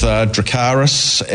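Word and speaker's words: Dracarys: Into race 4, there's been a lot of debate how we pronounce this horse's name. We're going to go with Dracaris out of Game of Thrones.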